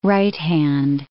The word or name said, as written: right-hand